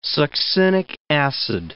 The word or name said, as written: succinic acid